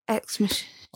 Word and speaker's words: Ex Machina: You ever watched that movie Ex Machinica? Is that how you say it? Machina? – Ex Machina.